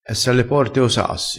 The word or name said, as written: esse alle porte o sassi